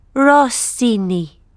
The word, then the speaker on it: Rossini: Rossini. – How do you spell that? – R-O-S-S-I-N-I.